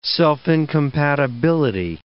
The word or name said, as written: self-incompatibility